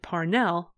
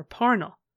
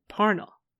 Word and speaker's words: Parnell: And further, in her memoir, Catherine sighed at all the people who said Parnell over Parnell, as Charles said Parnell.